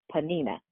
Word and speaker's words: Peninnah: Elky loved her way more than he loved his other wife, Peninnah. That's how you pronounce it. Peninnah.